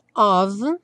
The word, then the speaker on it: of: Aqui, revisando of, no caso com som de V, esse F com som de V, e o love, como é, não é pronunciado, já vai ligar direto no O, né?